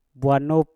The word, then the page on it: bwanup: Mpur